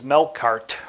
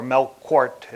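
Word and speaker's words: Melqart: In Tyre was Melkart, or Melkort is how it, the different pronunciations.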